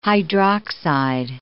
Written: hydroxide

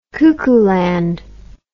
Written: cuckooland

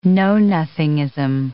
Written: know-nothingism

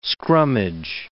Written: scrummage

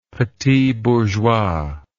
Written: petit bourgeois

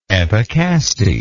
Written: epicaste

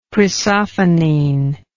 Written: chrysophenine

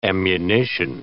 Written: ammunition

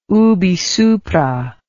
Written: ubi supra

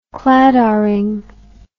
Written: Claddagh ring

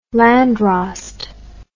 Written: landrost

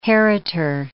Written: heritor

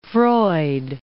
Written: Freud

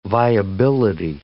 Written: viability

